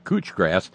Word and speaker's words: couch grass: pronounced cooch, oh, I'm sorry, cooch grass. So why the fuck isn't it spelled K-O-O-C-H?